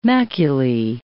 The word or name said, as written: maculae